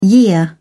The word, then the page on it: year: British English